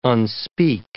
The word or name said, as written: unspeak